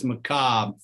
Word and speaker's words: macabre: I was saying that wrong because I was pronouncing the R in there, but it's just macabre, feminine macabre.